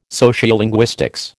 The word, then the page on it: sociolinguistics: American English